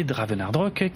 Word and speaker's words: Dravenardrok: Si vous voulez me retrouver personnellement sur X ou Blue Sky, mon pseudo c'est Draven et le nom du compte c'est Dravenardrock qui s'écrit D-R-A-V-E-N-A-R-D-R-O-K.